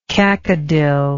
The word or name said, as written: cacodyl